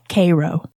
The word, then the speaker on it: Cairo: Yes, but there's a town called that is spelled like Cairo, but in the good old southern Illinois, Missouri way, we call it Cairo.